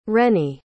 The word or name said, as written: reni